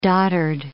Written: doddered

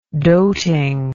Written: doting

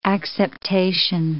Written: acceptation

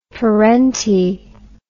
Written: perentie

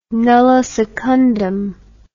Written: nulli secundum

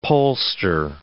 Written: pollster

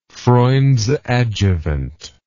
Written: Freund's adjuvant

